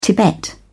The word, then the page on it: tibet: British English